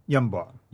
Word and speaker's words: Yanbu: We've got Yathrib, and then you have Yanbu, and you know, or Yanbu, or how do you pronounce that? – Yanbu.